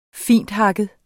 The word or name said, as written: finthakket